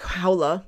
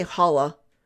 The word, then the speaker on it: Haula: You've got Erik Haula, because I will not say Haula, because I know that that's not how it's pronounced in Finnish.